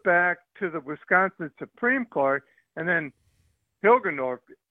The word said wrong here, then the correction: Hagedorn: or whatever his name is, Hilgeborn, or Hagedorn. – I might get the name wrong. – Hagedorn.